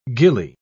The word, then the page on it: ghillie: British English